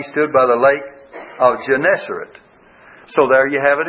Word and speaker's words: Gennesaret: G-E-N-N-E-S-A-R-E-T. Gennesaret, I guess, is the way you pronounce it.